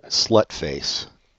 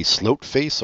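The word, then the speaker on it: Sløtface: I had to look up how to pronounce this. Did you see how you pronounce it? – No, how do you pronounce it? – Slutface. – Aww, I don't want to say that. – I know. I thought for sure it was going to be Slotface or Slotface.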